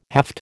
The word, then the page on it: haft: American English